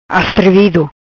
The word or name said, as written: Astrevido